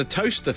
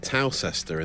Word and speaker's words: Towcester: The toaster thing, though, I used to pronounce it Towcester in the travel. And now I know it's toaster, and now I live very close.